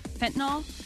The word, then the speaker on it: fentanyl: And now they're saying that the OxyContin that she was taking was laced with, I think it's fentanyl.